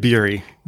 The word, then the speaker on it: Bury: Wyatt Bury.